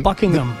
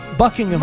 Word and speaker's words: Buckingham: Y de hecho, en inglés perfecto, el H medio que se es medio silencio. Buckingham. Buckingham. – Más House. – O menos. – No se pronuncia mucho.